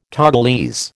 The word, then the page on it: Togolese: American English